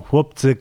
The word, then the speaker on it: Chłopcy: El nombre se describe de forma aparentemente muy distinta a como se pronuncia. Se escribe Chłopcy, algo así. O sea, una C, una H, una L con una rayita, una O, una P, una C y una Y.